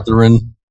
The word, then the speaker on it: Slytherin: Is that how you pronounce it, Riggins? – Yeah. – Slytherin? – Yeah, that's right.